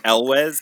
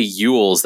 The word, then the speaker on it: Elwes: Elwes. It's just like it looks. Then I always fancier. – Yeah, I always say Elwes. I've listened to him say his name I've looked it up on YouTube just to be careful. And when people say Yules, that really like it. Like he says Elwes.